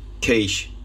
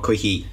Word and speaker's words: quiche: It's pronounced quiche, not quickie.